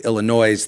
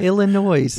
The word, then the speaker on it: Illinois: Pronounced the way it should be and not the way some people say with Illinois, thinking that the S is pronounced, which it is not, I assure you.